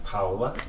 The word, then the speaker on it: Paola: How do you pronounce that name? – Paola. – Paola? – Paola. – P-A-O-L-A.